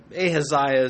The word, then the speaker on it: Ahaziah: His name, Ahaziah, and pronounced a couple of different ways, but Ahaziah is how I will try to stick with it this evening.